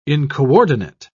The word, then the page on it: incoordinate: British English